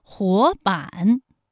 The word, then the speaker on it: 活版: 活版，第一个字是二声，第二个字是三声。